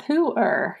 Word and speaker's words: Pu'er: You said pu'er? – It's called pu'er.